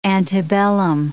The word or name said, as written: antebellum